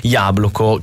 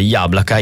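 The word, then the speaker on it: Yablaka (яблоко): perché Yabloko, che in realtà la pronuncia giusta sarebbe Yablaka in russo,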